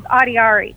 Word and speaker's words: Adiary: So my full name's Adiari, which is spelled A-D-I-A-R-Y.